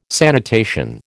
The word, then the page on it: sanitation: American English